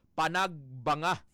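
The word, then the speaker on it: Panagbenga: Kag ang bantog man nga annual flower festival sa Baguio nga Panagbanga Festival kada Pebrero. Odong yes nga spelling is Panagbinga pero ang pronunciation sang mga igurot dito Panagbanga.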